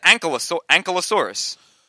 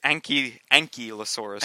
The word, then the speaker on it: ankylosaurus: I always said ankylosaurus. – Oh. – And apparently it is ankylosaurus.